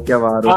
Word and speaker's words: Chiavaro: Giusto l'accento, e Chiavaro, no, Chiavaro. – Ah, vedi, beh, mi confondo sempre gli accenti.